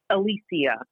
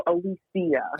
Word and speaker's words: Alycia: Alycia. See ya. Like, you're going to see me. So Alycia.